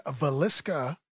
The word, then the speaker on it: Villisca: In Villisca, Iowa. Guys ever heard of that place? Probably not. – Not the way you pronounced it.